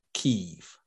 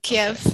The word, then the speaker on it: Kyiv: And actually, Vitali Klitschko, who I was talking about, he's the mayor of Kyiv. – Okay. Kyiv. – Okay. Kyiv, yeah. – Okay. – Well, I don't know. That's how they tell you they pronounce it.